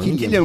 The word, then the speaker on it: Cillian: Killian, né? Murphy, eu falo. Killian, ok.